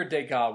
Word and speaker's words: Degas: Okay, so Edgar Degas? – Yeah. – Leave off the last S for savings? – Yes. – Okay. So, Edgar Degas was one of the classic impressionists,